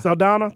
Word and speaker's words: Saldana: Is that how you say her name? – Zoe Saldana. – Saldana, thank you.